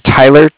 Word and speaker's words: Tyler: My name's Tyler, T-Y-L-E-R, okay. – And I'm calling with Cardiff. It's spelled C-A-R-D-I-F-F, as in Frank.